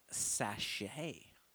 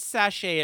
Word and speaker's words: sachet: But listen, also, Philip told me, and he educated me, so the word sachet is actually pronounced, are you ready for this? It's actually pronounced sachet. – Oh, I could buy that. I still would not have known because I'm an uncultured bitch. – And I'm also like not actually as gay as I thought I was, because he said, and I quote, call out to Philly. Philly, I love you. But he literally said, it's called sachet. It rhymes with ballet.